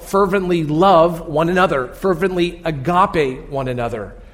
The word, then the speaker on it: agape: Now, one word for love is pronounced agape.